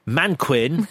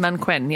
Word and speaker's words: Mannequin: And the movies are Manquin, I think it's pronounced. – Manquin, yes.